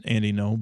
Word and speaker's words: Ngo: But it's definitely, it's pronounced Andy No, but.